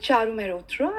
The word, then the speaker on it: Charu Mehrotra: So myself, Charu Mehrotra, I'm based in Singapore, and I am an entrepreneur for last 13 years.